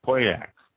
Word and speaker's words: Pauillac: Next region, just south of that, is Pauillac. It's spelled P-A-U-I-L-L-A-C, Pauillac.